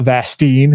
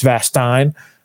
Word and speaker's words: Vastine: It's not Vastine, it's Vastine. So it's going to be that correction to be made moving forward.